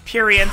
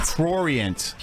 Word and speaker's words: prurient: Now, this week, I've been concentrating all week on how to say the word correctly. – Purient. – Prurient.